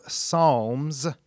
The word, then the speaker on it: Psalm: For example, in the Bible, there's the book of Psalms with an S, but if you're reading just one, you say it like Psalm, no S.